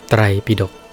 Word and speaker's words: ไตรปิฎก: ไตรปิดกปิดกแปลตามรูปศัพท์ว่ากระจาดตะกร้าไตรปิดก